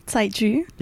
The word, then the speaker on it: 载具: 载具? – Yeah, that's pretty good.